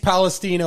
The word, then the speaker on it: Palestine: is the East Palestine, Ohio train crashes. And yes, it is pronounced Palestine, even though it's spelled like Palestine.